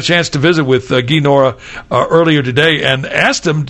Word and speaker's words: Nohra: And by the way, you spell his name G-U-Y, pronounced Guy, Nora, N-O-H-R-A.